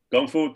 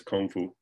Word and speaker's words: Gong Fu: Gong Fu to me means, is a, you know, the popularized pronunciation is Kung Fu.